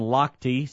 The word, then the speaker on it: Lochte: There would be Ryan Lochte, see how I know how to pronounce his name now, told an interviewer that he pees in the pool.